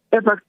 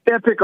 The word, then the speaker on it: Epoch: You'll believe something like one paper, Epoch Time, Epoch. – Yeah. – You can pronounce it epic or epoch. It don't matter. – Right. Yep. – It's either way.